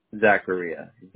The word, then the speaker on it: Zachariah: Zachariah, Z-A-C-H-A-R-I-A-H. – Can you spell that again? Z-A? – Z-H-A-R-I-A-H.